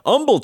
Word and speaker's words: Humble: They're watching us in Chandler, Arizona, Humble, Texas, the H is silent, Phenix City, Alabama, Lynn, Massachusetts.